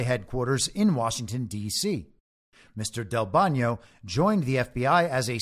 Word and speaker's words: Del Bagno: Del Bagno, and I think that this is the Italian pronunciation of his name. It's spelled Del Bagno, but I'm pretty sure that's Del Bagno.